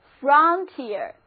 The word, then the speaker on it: frontier: 现在在 front 后面加上 ier 的名词后缀 frontier，注意字母组合 ier 发 ear 的音，单词就读作 frontier， frontier 就是前线，边境，同时也指学科研究中的新领域。